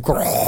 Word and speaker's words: Grohl: Grohl, yeah. – Well, I mean, his name pronounced as Dave Grohl.